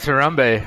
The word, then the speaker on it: Harambe: Harumbe? – Wasn't it something else, or was I pronouncing it wrong in my head the whole time? – Yeah, it's Harambe. Harambe? Harambe.